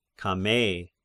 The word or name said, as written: Camei